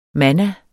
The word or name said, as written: manna